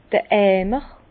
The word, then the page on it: d'r Eimer: Bas-Rhin; Herrlisheim